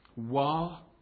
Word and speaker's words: waw: you'll see it's called W-A-W. Wah, not wah, but wah. And sometimes there is an alternative pronunciation, and you'll see some other places where it might be written V-A-V, Vav. And so it just depends where you learned your Hebrew as to what way you pronounce it. But I'm going to pronounce it Wah, and that's all we'll say about that.